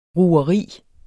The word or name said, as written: rugeri